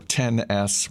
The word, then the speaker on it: XS: As you mentioned, the XS or 10S, whatever. I saw someone, I forget who, someone on Twitter spelled it out phonetically, saying, no, it's tennis, like the game tennis, as opposed to excess.